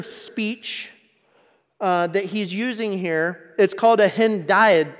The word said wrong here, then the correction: hendiadys: And let me see if I can make sure I pronounce this right. Hendiadys.